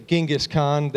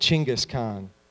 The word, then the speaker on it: Chinggis Khan: They were back in, we say Genghis Khan, they pronounce it Chinggis Khan.